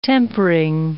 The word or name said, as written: tempering